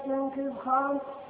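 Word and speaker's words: جنكيز خان: فسألت عنهم رفقتي وأحبتي حصاب جمع حزب جوكيز خان. – اترك لي يا أخي احدثها جوكيز خان بدنيا. خلت تبدأ بس أنت لا تنطق. – فسألت عنهم رفقتي وأحبتي أصحاب جهم حزب جنكز خان.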